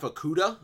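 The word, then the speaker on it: Okudah: It is definitely Jeff Okuda. Am I pronouncing his last name correctly? – Yep, I believe so, as far as I know.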